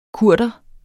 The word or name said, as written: Kurdere